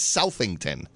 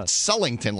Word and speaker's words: Southington: We had that debate actually last Friday when Brian in the morning show, we were out in Sullington, because it's spelled Southington, but no one says Southington. – Yeah. – Sullington, like there's a magical L that appears.